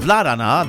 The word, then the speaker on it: Vladana: Or oh, Vladana, that's how I was told how to pronounce it.